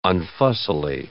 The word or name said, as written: unfussily